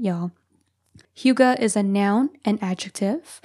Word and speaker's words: hygge: It is spelled H-Y-G-G-E, but pronounced hygge.